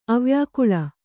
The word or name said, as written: ಅವ್ಯಾಕುಲ